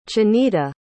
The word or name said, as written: chanita